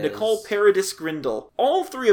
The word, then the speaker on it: Paradis: The only thing that's new as far as, like, notable credits people is Nicole Paradise Grindle. Which Paradise is spelled without an E, so it's like Paradis.